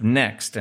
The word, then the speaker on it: NXT: NRF Next. And they cleverly spell it like the hipster kids, NXT.